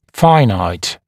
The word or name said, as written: finite